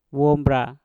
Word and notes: wombra: mpur

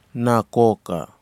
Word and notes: nakoka: Kwamera

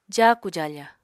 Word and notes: jaaku-jaafa: apatani

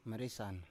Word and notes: maresan: Biak

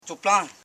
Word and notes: choplan: cho.plan

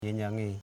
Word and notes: genyange: ge.nyang.e

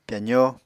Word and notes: pyazó: Apatani

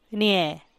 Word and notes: niee: Teotitlán del Valle Zapotec

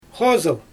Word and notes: xozɯl: Xyzyl

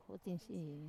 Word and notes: utin siri: Wamesa

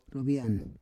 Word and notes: rovian: Roon

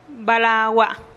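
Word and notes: balagua: Teotitlán del Valle Zapotec

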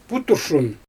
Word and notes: puturshun: Xyzyl

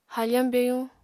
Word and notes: hafañ byayuñ: Apatani

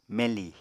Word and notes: meñli: Apatani

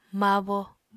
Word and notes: mabo: apatani

